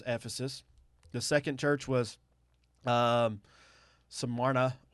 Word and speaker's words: Smyrna: Smyrna.